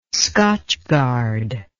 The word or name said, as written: scotchgard